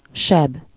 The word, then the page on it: verwonding: /vǝɾ.ʋɔn.dɪŋ/